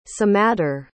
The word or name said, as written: semadar